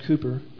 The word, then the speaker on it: Cowper: I think it's pronounced Cooper, but it's spelled like Cowper.